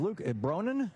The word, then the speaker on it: Luke Bronin: Here with you, broadcasting live from the DNC, and sitting here with me is Luke Bronin.